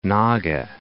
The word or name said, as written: Naga Hills